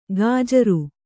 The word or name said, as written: ಗಾಜರು